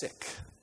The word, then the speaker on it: Siq: What the Edomites did is they found a sick. I think that's how you pronounce it, S-I-Q.